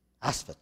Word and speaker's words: Asaph: Asaph, yeah, that's how you say it.